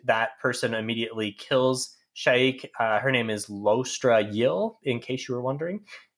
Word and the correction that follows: Lostara Yil: the narrator of the audiobook, who is obviously the ultimate authority on all things pronunciation, pronounces her name Lostara Yil. – Yeah, I forgot the T.